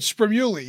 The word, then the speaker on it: Spremulli: Spremulli, yes. – Spremulli. It's like Italian. It sounds like Italian. – 100% Italian. That is correct.